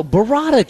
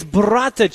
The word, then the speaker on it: burrata: You never heard of Bovada cheese? Is it Bovada? – No, burrata cheese. – It's Bovada. – No, it's burrata cheese. – Well, you don't have to roll your R's like a smartass.